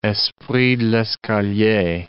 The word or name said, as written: esprit d'escalier